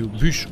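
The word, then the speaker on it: Bush: Donc c'est une bière qui est brassée parce que Bouche, c'est devenu Bûche. On dit Bûche en Belgique. Hein. On dit, ça s'écrit comme Georges Bouche, mhm. mais on dit Bûche.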